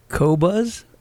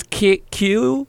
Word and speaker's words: Qobuz: It's pronounced Qobuz. Qobuz? – Qobuz? I don't know. – It's Qobuz.